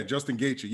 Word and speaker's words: Justin Gaethje: And how do you say the other guy's name? – Justin Gaethje? – Yeah, Justin Gaethje.